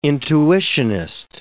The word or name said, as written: intuitionist